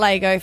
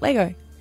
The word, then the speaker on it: Lego: Lego? – You said it right. – Not Lego for those from Adelaide, but Lego. The age of it was 5 to 7 year old.